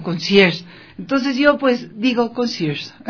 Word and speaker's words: concierge: Pues este ecuatoriano recibió el más grande reconocimiento para un concierge dentro de la industria hotelera global. Me dicen que así es que se pronuncia conserje en francés, y yo pues muy obediente lo digo.